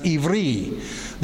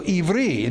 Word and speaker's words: Ivri: The name Hebrew comes from I-V-E-R-I, which would be pronounced Ivri. The I is pronounced as E, so Ivri.